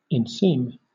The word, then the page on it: inseam: British English